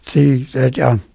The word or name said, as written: Tthi Tsehtyan